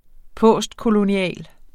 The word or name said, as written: postkolonial